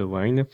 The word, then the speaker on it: Wine: Alors, ce qu'il y a, c'est que suivant les versions de Win que tu utilises, ou Wine, je sais pas comment on le dit en fait. Wine ou Win. – Ça s'écrit comment? – W-I-N-E, comme le vin. – Bah, Wine. – Wine. Bon, bah, Wine alors.